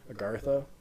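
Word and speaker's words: Agharta: It's A-G-H-A-R-T-A. Agartha? Well, the H is silent, I guess. – Yeah, sure. Sure. That's how I've heard it pronounced.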